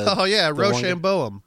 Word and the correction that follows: Rehoboam: I think it's Rohoboam.